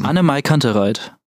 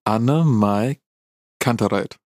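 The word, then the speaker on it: AnnenMayKantereit: Annemai Kantereit. – Annemai Kantereit. – Annemai Kantereit. – Annen, okay.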